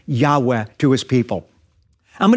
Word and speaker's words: Yahweh: So rather than being pronounced Yahweh, the church says Jehovah, but there is no God named Jehovah.